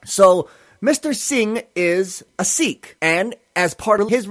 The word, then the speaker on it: Sikh: As a Sikh, that's S-I-K-H. Now, with respect to this word, I pronounce it Sikh. I've heard other folks pronounce it sick. I can't do it that way because of what the word sick means in English.